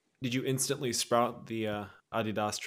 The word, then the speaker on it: Adidas: Yeah, as a certified Slav, I have to say everyone should own a pair of Adidas, which is the correct way to say it, of course.